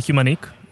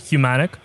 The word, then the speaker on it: Humanique: So it's actually Humanique, but some people do pronounce it as Humanic. It actually differs, as you obviously know, in English and French, you pronounce it differently. And since we operate in Africa, we decided that we're going to people the freedom and then decide whether it's Humanic or Humanique.